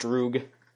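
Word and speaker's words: droog (друг): It looks like a peer, but it's pronounced droog.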